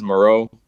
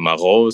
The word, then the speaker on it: Mareux: Yeah, I mean, the way I say it is Moreau. I think the French name, the French way is like Mareux or something like that. And then I've heard people say Maru and Marux and Merrick.